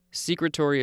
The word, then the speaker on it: secretory: Secretory is probably supposed to be pronounced secretory. It's the cells that secrete things.